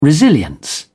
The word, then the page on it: resilience: British English